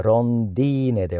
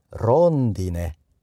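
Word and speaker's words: rondine: はい、次、ロンディネ、ツバメです。鳥のツバメです。絵で終わっていますが、女性名詞。だから複数形はロンディニ、はい。この言葉はアクセントの位置に気をつけましょう。ロンディーネではなくて、ロンディネ、ロンディニ、ツバメ。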